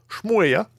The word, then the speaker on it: Schmooeya: Donc, on a le YouTuber sais ça se prononce. S-C-H-M-O-O-E-Y-A. Shmoya, et depuis plusieurs années considéré comme le meilleur des, euh, des joueurs de Guitar Hero au monde.